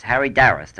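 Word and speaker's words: Harry d'Arrast: and both of those films were directed by Harry Darrast, is that the way you're... – Yes, Harry Darrast, I think, is the correct pronunciation, although what you've said is practically the same.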